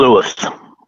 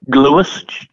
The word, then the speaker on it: Gluyas: How, how do you pronounce mm. it? Mm-hmm. – Gluis. – Say again. – Gluis, G L U Y A S, Gluis.